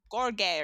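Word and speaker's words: Gheorghe: George. Georgie? – It's spelled George, but it's pronounced like Gorge or something beautiful.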